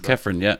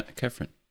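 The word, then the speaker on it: Khéphren: That's his name, isn't it? No. – Yeah, we'll go with that. Pronunciation isn't going to be great on this spot. – It's Kefram, Kefram. – Is it Turan, though? – Turan, yeah. Kefram Turan. – Kefram Turan.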